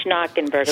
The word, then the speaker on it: Schnockenberg: Am I pronouncing your last name correct? – Yes, so it's Schnockenberg.